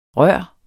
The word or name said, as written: rør